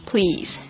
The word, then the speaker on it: please: Pillow low, O 这个声音哦， Pillow、所以呢你也可以这样说，很简单的这样说， One extra pillow please， One extra pillow please Pillow、注意一下这个发音哦、所以 please 就是请的意思，老师也纠正很多次不要念成 police、 police 就变成警察。